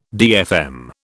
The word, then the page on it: DFM: British English